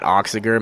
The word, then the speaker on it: Achziger: So I have my personal page, Matt Oxiger. My last name is spelled A-C-H-Z-I-G-E-R.